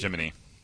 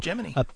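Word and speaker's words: Gemini: Kevin Bacon pronounced it Jiminy in the movie Apollo 13. – I know, because that's the correct. – Also been his poor That's the industry pronunciation.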